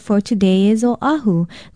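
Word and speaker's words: Oahu (Oʻahu): Oahu is spelled O-Okina-A-H-U. Although it is often disputed, many say that it means the gathering place. The main thing is to pronounce it correctly, Oahu.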